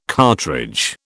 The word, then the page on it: cartridge: British English